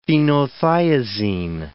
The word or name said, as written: phenothiazine